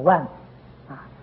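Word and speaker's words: 卍字: 所以武则天就把它念作万字，那么以后大家也就跟着念万字，这是三十二相之一。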